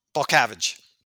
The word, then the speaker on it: Balcavage: Balcavage.